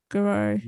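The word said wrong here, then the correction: Gurira: D-A-N-A-I. Please. I hope I'm pronouncing her name right. – I believe it's Denai Garrera. – Garrera, okay. – D-N-A Garrera.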